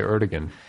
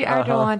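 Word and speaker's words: Erdoğan: I always want to say Erdogan, but it's, yeah, it's a silent G. – Oh, that's the, just, okay. Yeah. – It's a silent G, Erdogan.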